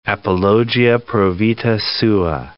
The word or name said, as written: apologia pro vita sua